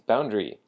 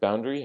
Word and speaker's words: boundary: Because it means boundary. So the word boundary has the emphasis on the first syllable, the boundary, boundary.